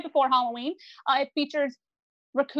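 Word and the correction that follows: Ricou: was that how you say it? Raku Browning? – Rico.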